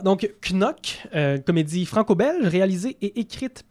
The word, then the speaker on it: Knock: Oui, en fait, il faut prononcer Knock. Je sais que... – Parce que c'est pas anglophone du tout. Oui, c'est spécial. On s'attend à ce que ce soit Knock, comme cogné sur la table.